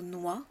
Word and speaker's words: noix: Alors le premier évidemment c'est le mot noix. On ne prononce pas le X au bout du mot noix.